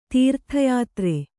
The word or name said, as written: ತೀರ್ಥಯಾತ್ರೆ